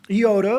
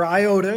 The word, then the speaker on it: iota: The iota, or iota as we pronounce it in English, is the smallest letter in the Hebrew or the Greek alphabet.